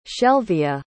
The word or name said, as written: shelvia